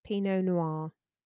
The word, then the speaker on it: Pinot Noir: Pinot Noir. – Pinot Noir.